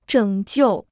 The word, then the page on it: 拯救: zhěng jiù